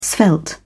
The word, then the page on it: svelte: British English